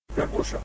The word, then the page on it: garbusha: Ninilchik Russian